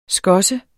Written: skosse

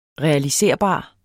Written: REALISERBART

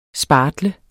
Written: SPARTLET

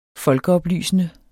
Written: folkeoplysende

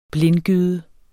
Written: blindgyde

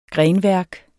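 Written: grenværk

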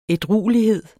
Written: ædruelighed

